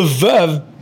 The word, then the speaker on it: Veuve: Veuve Coquelicot. – Veuve Coquelicot? – that's just not the same. – So the correct way to pronounce it is veuve. So veuve. And then Clicquot.